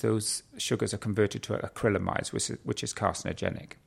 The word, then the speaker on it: acrylamides: The other big group of carcinogens are called acrylamides, which is how you say it.